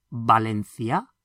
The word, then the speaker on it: Valencia: And from Valencia, you'll notice that the letter V is pronounced like a B, because V's in Spanish are always pronounced like B's. So, ba, Valencia. Estás en Valencia?